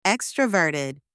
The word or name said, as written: extroverted